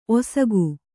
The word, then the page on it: ಒಸಗು: osagu